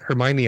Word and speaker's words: Hermione: So when I first read the Harry Potter books, you know, seeing the word Hermione. – Oh yeah, if it's phonetically the way it's spelled. – Phonetically, you're like, is the for the longest time I called her like Hermione, Hermione, Hermione. – And then when I saw the first movie, and they're like Hermione, I'm like, that's how you say it.